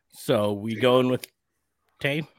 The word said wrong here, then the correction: Tay: Ty, sorry, Ty Conti. – Ty Conti. Ty. Sorry, said it wrong. – I have such a hard time calling her Ty, if only because I know that's how it's pronounced.